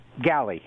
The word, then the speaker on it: Galley: Galley, as in a ship's galley.